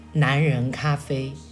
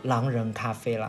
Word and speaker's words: 狼人咖啡: 反正当时张云金同学就提议我们去这家叫，嗯，以他的发音来读的话就是男人咖啡，其实是嗯叫狼人咖啡啦。